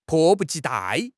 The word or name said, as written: 迫不及待